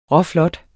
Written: råflot

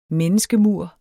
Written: menneskemur

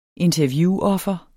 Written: interviewoffer

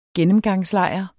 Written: gennemgangslejr

